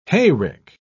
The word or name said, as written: hayrick